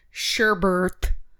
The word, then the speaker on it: sherbet: and then they've got scoops of sherbet. It's a silent R. – It's an invisible R.